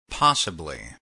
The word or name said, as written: possibly